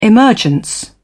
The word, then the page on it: emergence: British English